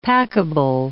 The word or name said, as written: packable